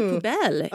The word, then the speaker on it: poubelle: A la poubelle.